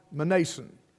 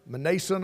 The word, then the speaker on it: Mnason: he called him Mnason. So pronouncing the M and the N, they're not commonly together. Mnason of Cyprus, an old disciple with whom we should lodge.